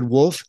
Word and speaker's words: Wolf: So, David Wolf is my name, spelled like the animal, W-O-L-F.